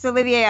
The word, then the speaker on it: Olivier: Yeah, and I saw on the site too that your site you had an interview with the writer and director Oliver Assayas. Am I saying that right? I'm so sorry. – I think it's Assayas. – Assayas. – It's Olivier Assayas.